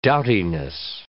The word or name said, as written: doughtiness